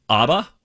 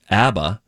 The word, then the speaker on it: ABBA: You know the band ABBA? Or if you're correct in pronouncing it, I believe it's ABBA.